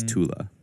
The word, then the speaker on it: Thule: The E is a schwa. So it's pronounced very softly, like the A in sofa. So yeah, it is Thule.